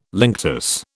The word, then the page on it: linctus: British English